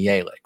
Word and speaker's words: Yelich: Like Christian Yelich. It's obviously Yelich, right? – It is.